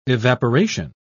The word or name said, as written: evaporation